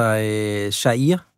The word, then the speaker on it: Jair: Og jeg ved slet ikke, om jeg udtalede Share Bolsonaros navn rigtigt, eller oversatte helt korrekt, hvad det var, Flavio her fik sagt i sin jiu-jitsu-dragt. – Jo, det tror jeg. Altså, Jair. Altså, Jair.